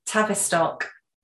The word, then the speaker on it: Tavistock: I always say that wrong. Is it Tavistock? Tavistock. Tra- it's Tavistock. – Tavis Tavistock. Okay. – Um, Tavistock. Tavistock. – Tav Tav Tavistock. Tavistock. There you go.